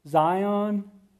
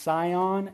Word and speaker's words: Zion: Jerusalem, Zion, can be pronounced Sion as well.